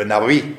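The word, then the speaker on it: Navi (נביא): Intanto il termine che viene usato come termine ultimo, il più recente, che viene usato per indicare il profeta, è il famoso Nabi. Gli ebrei la B la pronunciano V e quindi diventa Navi, ma è una B, è la B aspirata, cioè Navi.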